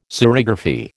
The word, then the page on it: serigraphy: American English